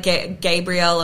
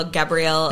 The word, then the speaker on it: Gabrielle: No, like people say it like differently. They're like Gabrielle or Gabrielle.